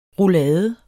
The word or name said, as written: roulade